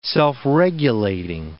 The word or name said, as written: self-regulating